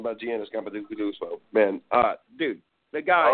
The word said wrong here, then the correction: Antetokounmpo: Oh, please tell me you can finally pronounce his name correctly. Let's try that again. – Antetokounmpo, bro, and Team Pompo.